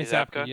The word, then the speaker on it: Zabka: How do you say his last name? Zabka. Is that it? – Johnny Zabka. Yeah.